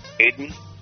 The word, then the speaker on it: Aeden: How would you pronounce the first name spelled A-E-D-E-N? A-E-D-E-N. Would that be Aiden? – Aiden? – Aiden?